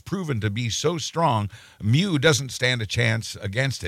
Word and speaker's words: Mu: Greek pronunciation is Mu. – People have been associating it with cows when they should be associating it with cats.